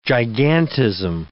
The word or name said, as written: gigantism